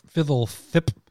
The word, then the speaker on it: FBLTHP: How do you spell it? – FBLTHP. – That's right. Fiddle thip.